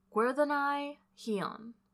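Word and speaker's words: Gwerddonau Llion: The second possible origin is an island in the Irish Sea known as Gwerddonai Hion, and that's spelled G-W-E-R-D-D-O-N-A-U, new word, L-L-I-O-N, which means the Isle of Enchantment.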